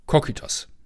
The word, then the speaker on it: Kokytos: Und wenn man den runterspringt, dann darunter springt, dann kommt man nach Kokitos. Kokytos. Ich glaube, im Griechischen betont man immer die drittletzte Silbe.